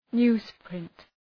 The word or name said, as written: newsprint